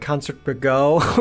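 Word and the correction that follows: Concertgebouw: or whatever it is. – Concert Gabot.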